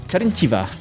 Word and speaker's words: Terenteva: This is Internet Marketing, and today I'm joined by Elena Terentyeva, content strategist at SEMrush.